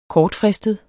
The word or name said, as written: kortfristet